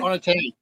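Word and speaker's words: Onetangi: Onatangi. Yeah, pronounce it right. – Onatangi is perfect, perfect pronunciation. Exactly right.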